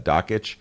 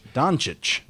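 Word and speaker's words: Dončić: Luka Doncic. – Doncic. – No, I think the N is silent. I think I'm right in this case, and you're wrong. – So you think it's Doncic? – Doncic.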